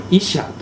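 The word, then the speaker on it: イ シャー (אשה): すると人は言った、これこそ今や私の骨からの骨、私の肉からの肉、これを女、すなわちこれイシャーと発音します。イシャーと名付けよう。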